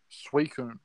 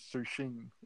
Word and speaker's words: Suicune: Lugia was silver, and Crystal was um, Suicune, which as a kid I pronounced as Sushin.